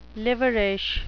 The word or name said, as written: liverish